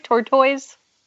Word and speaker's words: tortoise: Tortoise? – Tortoise. – Tortoise. – Yes, like the word tautology. Tortoise.